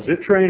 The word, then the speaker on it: Zittrain: Question for is it Professor Zittrain, or am I pronouncing it properly? – That works for me.